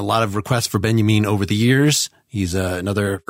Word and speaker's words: Benjamin: This Walter Benjamin essay, spelled Benjamin, pronounced Benjamin, was, I believe, the first thing that she cited in there.